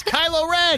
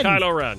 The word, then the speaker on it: Kylo Ren: Rhymes with Shmilo Len. – Oh, Milo Len. – Mm-hmm. – A Milo? – Tell her what. – That's Kylo Ren. – Kylo Ren.